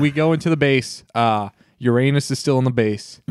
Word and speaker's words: Uranus: Whoa, it's Uranus. Thank you. – Yeah, please get the pronunciation right.